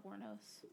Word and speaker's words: Wuornos: Oh, Wuornos. – Wuornos. – Wuornos.